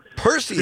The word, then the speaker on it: Perseids: Well, first of all, as an amateur astronomer, I'll tell you that the meteor shower is the Perseids. – Perseids. Three syllables. Fantastic.